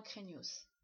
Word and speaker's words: craignos: Il y a toujours un S à la fin qu'on prononce. C'est vraiment craignos.